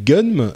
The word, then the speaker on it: Gunnm: Donc euh, voilà, ça s'appelle Gunm.